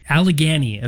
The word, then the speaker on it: Allegany: Allegory. – It wasn't Allegory. – Allegany is how I called it. – Can you spell it for everybody? – A-L-L-E-G. Oh, hold on. A-N-Y.